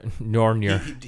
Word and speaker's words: Mjolnir: Mjolnir. – Mjolnir.